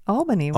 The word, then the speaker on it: Albany: Albany. How do you say Albany or Albany? Albany. Where Albany.